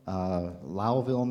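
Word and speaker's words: Lowville: Okay, so the first one from Princeton Seminary, I went up to upstate New York, Lowellville, New York, rhymes with Cowville.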